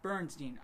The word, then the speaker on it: Berenstain: It's not Bern, though. It's Beren. – Berenstain. Berenstain.